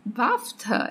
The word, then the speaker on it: BAFTA: Ele apareceu também no BAFTA, é assim que se pronuncia esse rolê? – Isso.